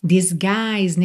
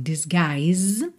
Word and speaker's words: disguise: Disguise, nesse G, o I, olha, o U não é pronunciado, esse I é aberto, longo. Disguise.